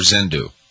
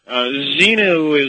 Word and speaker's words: Xenu: Does he pronounce it Zenu or Zendu? Up with it, right? His ex-wife. – Right. – Yeah, no, it's crazy. – Zenu.